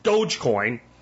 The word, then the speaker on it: Dogecoin: the right way to pronounce Dogecoin. There's no A in there. If you can't pronounce Dogecoin, you have no business talking about cryptocurrency.